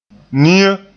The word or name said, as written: nia